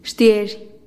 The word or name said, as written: xtiezh